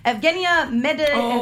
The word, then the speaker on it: Evgenia Medvedeva: We already mentioned Evgeny Plushenko being a big supporter of it, but Russian figure skater Evgenia Medvedeva.